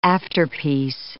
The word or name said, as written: afterpiece